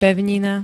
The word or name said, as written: pevnina